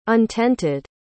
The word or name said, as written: untented